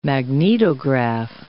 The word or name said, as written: magnetograph